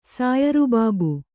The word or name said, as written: ಸಾಯರಬಾಬು